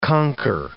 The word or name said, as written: conquer